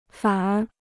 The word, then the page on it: 反而: fǎn ér